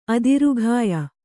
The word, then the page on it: ಆಧಾರಗೆಯ್: ādhāragey